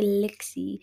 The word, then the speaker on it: Lixie: I said Lixie. If you can't spell it, just ask me, and I'm gonna be like L I X I E, not L I K S Y.